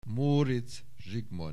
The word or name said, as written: Móricz Zsigmond